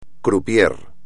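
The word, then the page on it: croupier: español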